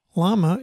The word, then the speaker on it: llama: Hey, Abby, did you know that the LL in llama should be pronounced like a Y? So llama is llama.